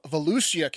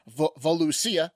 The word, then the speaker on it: Volusia: Allison Murphy of Volusia County. I was told by the wife I mispronounced that one pretty bad last week. I was saying Volusia. It's Volusia County.